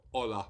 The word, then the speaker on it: Hola: Hola. There's not a H in there, is there, surely? I didn't hear a H. – Silent H. – It's a silent H. – It's a soft H. – So how's that spelled? – H-O-L-A, kindness. – H-O-L-A, kindness.